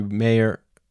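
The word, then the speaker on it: Mayer: Matthew Meyer, actually, that's how you pronounce it. Spelt Meyer, but pronounced Meyer.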